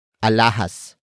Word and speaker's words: alahas: And that object is alahas. Alahas. Alahas. Alahas is spelled as A-L-A-H-A-S.